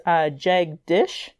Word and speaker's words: Jagdish: But because my candidate's name is Jagdish. So, that's what it looks like. It's Jag and a dish. It's actually not incorrect to be saying a Jag.